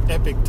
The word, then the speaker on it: Epoch: This is Epoch Times, or they call themselves Epic Times, but it's spelled E-P-O-C-H Times.